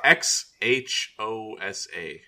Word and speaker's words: Xhosa: It's a X, X, X, H, O, S, A. – Yeah, Xhosa. – It's a Xhosa? – Xhosa. – Okay. – Yeah, I'm looking at the phonetic. Xhosa. – It's got click consonants.